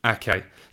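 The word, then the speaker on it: Aké: And I was more than slightly taken aback when Ake replied that in Dutch, it's pronounced Nathan Ake.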